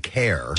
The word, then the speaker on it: Kerr: He pronounces his name Jim Kerr. – Jim Kerr. – But we've always called him Kerr throughout the years.